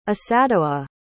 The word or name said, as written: esadowa